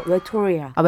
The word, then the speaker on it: 외톨이야: 발음을 정확하게 해줘야 돼요. 웨토리아. 아, 웨토리아. – 아, 네, 웨토리아. 웨토, 이아라고 하면 안 되는 아, 아, 거잖아.